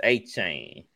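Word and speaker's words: Achane: It's A-Chain. That's how I think that's how you want to people pronounce his name now.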